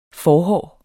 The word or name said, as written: forhår